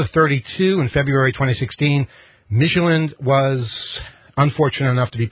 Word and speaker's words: Midtjylland: That got Southampton to the playoff round, where they wound up losing to Denmark's Midtjylland. You knew how to pronounce that better than me, Kyle. Midtjylland. – Midtjylland? – Yeah, Danish team.